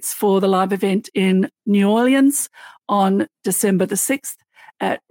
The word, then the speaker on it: New Orleans: I love how Sarah I'm- says New Orleans, by the way.